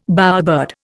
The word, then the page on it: bowerbird: Indian